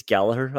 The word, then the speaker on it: Gallagher: You said Matip and uh, I hear a lot of commentators and English people say Gallagher. I have to tell you, it's Gallagher. I'm happy to correct people.